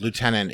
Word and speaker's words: lieutenant: The British pronunciation of lieutenant is very annoying. there's no f in there.